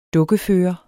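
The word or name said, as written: dukkefører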